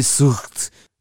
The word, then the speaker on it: Witzelsucht: Yes, I know it's properly Witzel and it's properly Sucht, but my pronunciation is funnier.